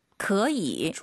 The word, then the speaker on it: 可以: 可以。 – 좋아요. 삼성이 두 개니까 앞에 그, 그 다음 반삼성, 그이.